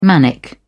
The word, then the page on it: manic: British English